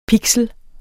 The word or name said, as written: pixel